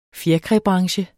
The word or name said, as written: fjerkræbranche